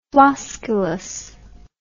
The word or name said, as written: flosculous